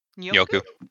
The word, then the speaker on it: Njoku: Njoku.